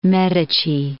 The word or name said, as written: Medici, de'